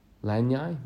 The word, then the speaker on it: lanai: Lanai? Okay. – That's how it's pronounced.